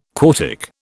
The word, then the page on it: quartic: British English